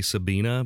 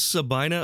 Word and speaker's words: Sabina: It's spelled like it should be Sabina, but from what I've heard, it's pronounced Sabina.